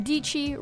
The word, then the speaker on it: Dici: My name is Sarah Dici, rhymes with peachy.